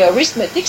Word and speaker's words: arithmetic: et arithmetic, c'est encore plus subtil parce qu'en anglais il y a des accents toniques et que la première syllabe accentuée c'est rith, donc c'est le R, donc on appelle ça les trois R, c'est-à-dire en gros, donc lecture, écriture et calcul.